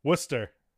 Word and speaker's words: Worcester: Worcester, Massachusetts. Worcester. Say it again. – Worcester.